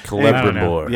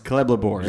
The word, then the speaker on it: Celebrimbor: Celebrimbor. – Yes, Celebrimbor. – You gotta say it with confidence. – I don't know. I can't. Anyway, so Celebrimbor.